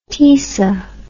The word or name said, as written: Tisa